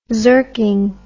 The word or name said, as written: zerking